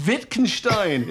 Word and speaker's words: Wittgenstein: I'm yelling at my phone going, Wittgenstein, it's Wittgenstein, it's Nietzsche, or whatever it was.